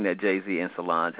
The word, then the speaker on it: Solange: Is that how you pronounce her name, Solange? – Yeah.